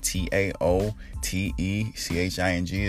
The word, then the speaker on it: Tao Te Ching: It's spelled Tao Te Ching. It's just pronounced with the D.